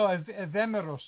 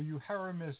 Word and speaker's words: Euhemerus: No, Evemeros, or Euhemerus, as he's pronounced in English, he wrote about that extensively, about how people can become gods during their own lifetimes.